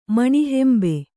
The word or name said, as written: ಮಣಿಹೆಂಬೆ